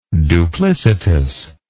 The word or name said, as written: duplicitously